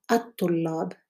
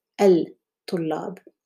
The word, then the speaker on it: الطلاب: Note that in the second example, الطلاب, we do not pronounce the L in the definite article L. We say الطلاب, not الطلاب.